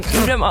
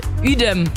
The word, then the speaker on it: Uedem: Er sagt, Uedem, was ich von Uedem ausgesprochen habe, wird Uedem ausgesprochen.